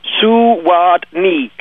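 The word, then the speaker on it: Zawodny: From the chat room and the pronunciation of the name. So it's Zuwadni. – Zuwadni. – According to the chat room.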